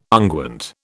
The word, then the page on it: unguent: British English